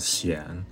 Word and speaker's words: Xian: So my first name is pronounced as Xian, and my last name is Zhao.